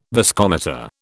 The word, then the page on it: viscometer: British English